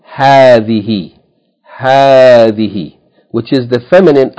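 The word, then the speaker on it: hadhihi (هذه): Yani, this word is pronounced hadhihi, hadhihi, with a long vowel.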